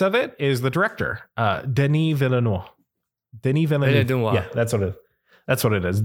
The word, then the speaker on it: Villeneuve: I honestly had to look up how to pronounce his name today because before today I was using my Kentucky brain, and I've been pronouncing it in like a full Peggy Hill voice, just Denis Villeneuve.